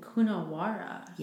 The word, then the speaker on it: Coonawarra: So this is from a plot of land in Coonawarra.